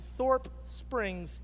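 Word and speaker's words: Thorp Spring: And in fact, in 1873, in a little town called Thorpe Springs, I said it wrong again. I was corrected after the first service. There's only one spring in Thorpe. In Thorpe Springs, Texas, which is about 40 miles from here.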